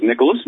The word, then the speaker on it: Nicholas: My full name, full first name is Nicholas. My middle name is Anthony. Last name is Wright, W-R-I-G-H-T. So Nicholas is spelled N-I-C-H-O-L-A-S, and Anthony, A-N-T-H-O-N-Y, and of course Wright, W-R-I-G-H-T.